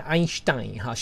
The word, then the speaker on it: Einstein: Einstein 在德语我觉得发音像 Einstein， Stein 这个字超酷的呢，这个字可以当宝石呢，所以感觉像是一颗宝石呢。我们看例句， Albert Einstein 献身于物理学。